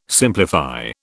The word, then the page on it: simplify: British English